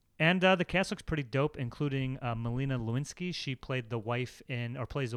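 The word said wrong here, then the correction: Lynskey: How did you pronounce it? – Lewinsky. Is that wrong? – No, it's Linsky. – Linsky.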